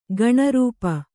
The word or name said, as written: ಗಣರೂಪ